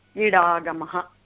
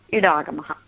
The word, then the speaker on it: इडागमः: तत्र किं भवति? इट् आगमः इडागमः. डकारस्य स्थाने डकारः अभवत्. इत्युक्ते सन्धिः अभवत्. तदर्थं तत्र उच्चारणं कथं भवति? इडागमः, इट् आगमः, इडागमः.